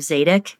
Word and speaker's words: Zadok: and specifically to the descendants of Zadok, and I think I'm pronouncing that right.